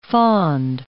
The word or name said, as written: fond